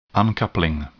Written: uncoupling

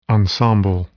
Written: ensemble

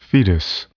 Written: fetus

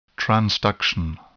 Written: transduction

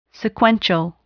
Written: sequential

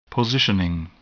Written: positioning

